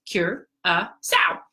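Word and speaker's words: Curaçao: So CURACAO, as you can see, is spelled CURACAO, pronounced CURACAO.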